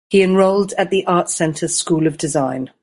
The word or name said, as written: enrolled